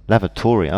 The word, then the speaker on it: lavatory: Okay, so lavatory. – You stress the last part, lavatory. Okay. – And you say? – Lavatory. – Lavatory. – So we stress the first part, the first syllable.